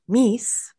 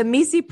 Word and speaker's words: MECE: So it's like pronounced by many as MECE and pronounced by the author as Mies. Um, okay. the MECE principle has been used in the business mapping progress, wherein the optimum arrangement of information is exhaustive oh, okay.